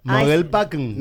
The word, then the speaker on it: Mogelpackung: En alemán se pronuncia así. Mogelpackung.